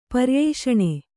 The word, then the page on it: ಪರ್ಯೂಷಣ: paryūṣaṇa